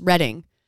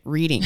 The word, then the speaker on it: Reading: I was in Redding. Also it's pronounced Redding. – Oh, like in California, Redding? – Yes, but it's spelt Reading.